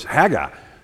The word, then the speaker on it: Haggai: Some people call it Haggai, kind of like you draw it out. You know, if you're in the South, maybe we make everything multiple syllables. Have you noticed that? But I think the pronunciation of this word is Haggai.